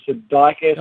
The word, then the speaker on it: Sudeikis: Um, comedian Jason, I can't pronounce his last name, Sudeikis.